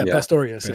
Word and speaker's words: Pastorius: It's Pastorius, right? Pastorius? – Okay. I don't know. – Yeah. Pastorius.